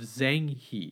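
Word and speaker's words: Zheng He: And, you know, one of the people that sort of came to my mind was a famous explorer by the name of Zhang He, and that's spelled Zheng, and then He, which is He.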